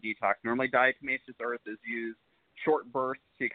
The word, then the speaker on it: diatomaceous earth: Diatomaceous earth.